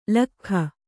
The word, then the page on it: ಲೊಕ್ಕಿಗ: Kannada